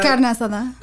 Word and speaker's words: Carne Asada: Carne Asada.